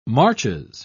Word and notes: marches: British English

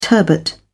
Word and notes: turbot: British English

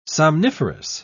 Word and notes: somniferous: British English

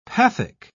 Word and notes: pathic: British English

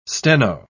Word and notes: steno-: British English

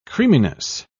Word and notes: creaminess: British English

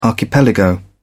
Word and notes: archipelago: British English

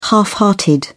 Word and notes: half-hearted: British English

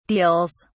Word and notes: deals: British English

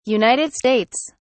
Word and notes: United States: British English

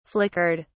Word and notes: flickered: British English

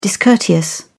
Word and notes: discourteous: British English

